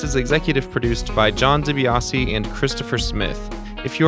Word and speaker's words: DiBiase: And it is not pronounced Debias.